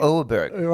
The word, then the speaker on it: Åberg: Åberg.